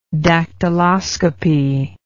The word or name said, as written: dactyloscopy